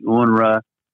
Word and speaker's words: UNRWA: And so I'm there and I've handed her a list of these questions regarding anti-Semitism issues, the anti-Semitic UN agency, UNRWA, pronounced that way, or with reference to the Jewish cemetery desecrations.